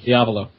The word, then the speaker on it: Diavolo: Diavolo.